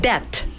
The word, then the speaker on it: debt: Because some of the people who build the businesses, they come from debt. – Debt? – That's how you pronounce D-E-B-T.